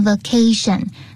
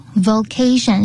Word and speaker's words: vocation: 就是呢，我比较喜欢的职业，这边职业是 vocation，那千万不要念成 vacation，就只差一个这个字母而已，就 V-O 跟 V-A， vocation 是职业。